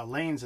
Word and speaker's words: Lainz: Now, Lanes is L-A-I-N-Z, and this is in Austria. So, I believe I'm saying it correctly. Lanes, like bowling lanes.